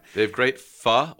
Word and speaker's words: Pho: It's called now, I know it's pronounced Pho, right? But I feel really pretentious. I think we've discussed this on the show. I feel very pretentious saying that. So I'm going to say it's called Pho Na Trang.